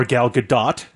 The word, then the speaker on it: Gadot: Or Gal Gadot, depending on how you want to pronounce it. – Will we accept that? – Yes. Because that is technically how you're supposed to pronounce it.